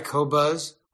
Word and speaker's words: Qobuz: It's available in many countries, and it's spelled Q-O-B-U-Z, Q-O-B-U-Z. It's pronounced Qobuz.